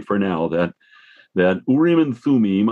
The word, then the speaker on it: Urim and Thummim: And as I understand it, you're much better at Hebrew than I am, but I think that's approximately how it ought to be pronounce Urim and Thummim, but the words mean lights in the plural and perfections.